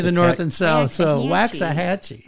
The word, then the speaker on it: Waxahachie: Saying you were going to Waxahachie. – Yes. – Did I say that right? – I think so.